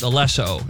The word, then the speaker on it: Alesso: It's actually pronounced Alesso, and he's just the guy who makes the beats.